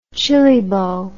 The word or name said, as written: chili bowl